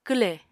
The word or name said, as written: kwlè